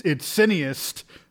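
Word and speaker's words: cineaste: I hate the fact that you're calling this back, because I actually mispronounced it. It's cineast.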